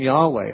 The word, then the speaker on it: Yahweh: Yahweh is one way to pronounce it. Jehovah is another way to pronounce it, but Yahweh is closer to the Hebrew way.